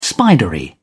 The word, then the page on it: spidery: British English